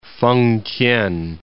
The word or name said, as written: Fengtien